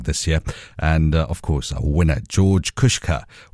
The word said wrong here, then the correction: Kusha: George Kusha, that's how he pronounces his surname.